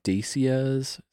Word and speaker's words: Dacia: We got like, we got out to the airport, and there's a lot of like Renaults and Dacias.